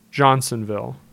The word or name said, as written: Johnsonville